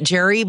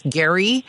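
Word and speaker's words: Gerry: I would have pronounced it Jerry, but the gentleman or woman who texted says it's Gary.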